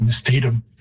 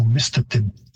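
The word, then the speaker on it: mistatim: And you've got a stress shift because English tends to like, many words are stressed on the second last syllable, so you get Mistatum. And the Cree stress or pitch system would have a higher pitch on the first syllable, so Mistatum.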